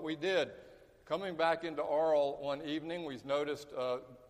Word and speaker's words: Arles: Arles is how they say it in France. You don't say Arles, it's Arles.